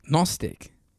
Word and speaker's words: Gnostic: Gnostic. – Gnostic? Well, is it silent G then? – Yeah, it's silent G.